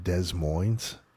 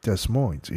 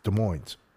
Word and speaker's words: Des Moines: Do you know the S is silent in Des Moines? – I did not know that. – Dude, I said to the guy, I was like, Des Moines.